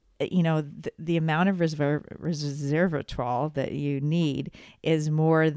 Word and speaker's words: resveratrol: Because it's the red skins in the red wine, the grape skins, that give us the resveratrol. Did I say that right? – That's pretty close. That's a tough resveratrol,